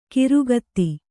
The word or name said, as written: ಖುರಗತಿ